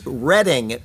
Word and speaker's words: Reading: And Reading. I've said Reading. I know that it's Reading. And I convinced myself that I was going to say it correctly, and then I said the opposite. It is Reading, Pennsylvania, like the Reading Railroad on the Monopoly set.